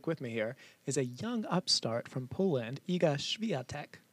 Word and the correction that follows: Iga Świątek: Well, first of all, I butchered her name. I'm sorry. It's Iga Świątek. It is not spelled that way, but languages do things differently, as we're learning from Holland.